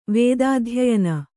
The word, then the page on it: ವೇದಾಧ್ಯಯನ: kannada